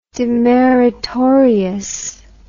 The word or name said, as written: demeritoriously